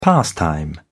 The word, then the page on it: pastime: British English